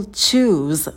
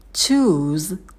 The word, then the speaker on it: choose: Первый глагол choose на звуке ч. Язык стремится наверх, потом долгий у. Мы не вытягиваем сильно губы вперед, стараемся произносить этот звук через оскал и создаем звуковую волну. У, у, choose, choose. И в конце звук з будет звонким, не оглушайте его.